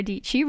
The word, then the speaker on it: Dici: My name is Sarah Dici. Rhymes with Peachy.